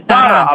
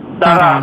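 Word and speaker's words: Tara: Не Тара, а по-персидски ударение Тара на последний слог. На второй, да.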